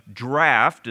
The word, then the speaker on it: draught: let down your nets for a draught. It's an old English word that would be pronounced like our English word draft, which means like a depth or a let it down into the deep.